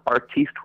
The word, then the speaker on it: artiste: the word artist was properly pronounced as it is in his home country of wherever he's from. Um, and so he wants to be, have it pronounced as what? um, artiste would be uh, would be suffice.